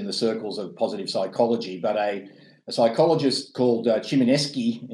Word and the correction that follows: Csikszentmihalyi: you know, I would have mispronounced it and I apologize. – It took me 3 years doing my master's. Csikszentmihalyi.